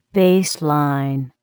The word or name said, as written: baseline